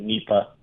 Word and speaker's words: Nipah: Nipah virus. Am I saying that right? – Yeah, it's Nipah or Nipah.